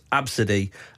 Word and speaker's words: Abcde: Well, to be fair, Della, who messaged, says, Jason and Sarah, the ABCD person's name is pronounced Abcde.